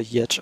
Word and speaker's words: Giertz: Auf Schwedisch spricht sich das wohl Yerch aus.